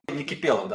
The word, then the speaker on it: Никипелов: А, Никипелов, да, правильно. Я тоже на, на, ударении споткнулся.